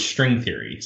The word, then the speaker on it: Strength Theory: For the exact reason of how you pronounced it, you said Strength Theory. It was String Theory. So it was a pun. If you'll go back and look at the old URL, if you can find one anywhere, there's not a double TH. And it's pronounced like String Theory, like physics.